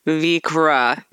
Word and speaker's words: Vikre: All right, Emily, what's next for you and what's next for Vikre Distilling? Did I say it right? Did I do it right? – Yes. Beautiful. Well done.